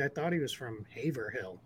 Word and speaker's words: Haverhill: It looks like it says Haverhill or Haverhill or Haverhill. It's Haverhill is how it's said in Massachusetts. So live with it. That's where the city is located. That's how we say it.